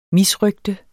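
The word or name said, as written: MISRØGTER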